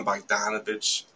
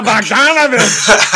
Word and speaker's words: Bogdanovic: Bojan Bogdanovic stepped up a lot and filled the holes. – It's good pronunciation. – Thank you. I yell it a lot at the TV, so... – Ah, Bogdanovic!